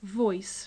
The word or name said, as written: voice